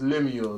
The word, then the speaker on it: Lemuel: It's Lemuel, like Lemuel Gulliver.